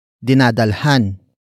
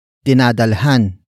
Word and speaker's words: dinadalhan: The first word is dinadalhan. Dinadalhan. Dinadalhan. Dinadalhan is spelled as D I N A D A L H A N.